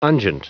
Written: unguent

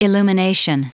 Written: illumination

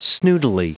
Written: snootily

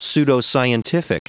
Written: pseudoscientific

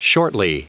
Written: shortly

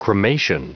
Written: cremation

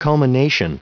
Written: culmination

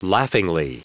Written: laughingly

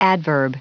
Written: adverb

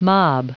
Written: mob

